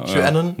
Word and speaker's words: QAnon: Wir haben jetzt ja gerade diese QAnon oder wie spricht man es richtig aus? Der wäre schon der nächste Punkt. – QAnon.